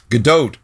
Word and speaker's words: Godot: I have it on good authority that it Godot, it rhymes with afloat. – Ah, so, okay, very good. – Yeah, because I was trying to pronounce it with a silent T, like it was French or something.